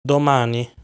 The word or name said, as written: Domani